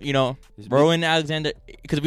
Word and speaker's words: Rowan: I think that's how you pronounce it. R-O-W-A-N. Rowan.